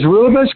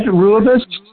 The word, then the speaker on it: Rooibos: Is Rooibos, remember red Rooibos? Rooibos is spelled R-O-O-I-B-U-S, Rooibos.